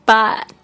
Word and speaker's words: baht: 然后呢，如果你听到呃美国人或英国人他们会这样说，他们会说 but but but 那个 but 那个 a 它的拼法是 b a h t，但是呢就是那个 a h 那个 h 没有发音的，就是 but but。